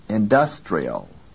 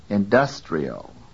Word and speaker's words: industrial: 9. Listen to the quality of the vowel in the stressed syllable in this word. Industrial. 10. The vowel in the syllable dus is pronounced with full quality. Industrial.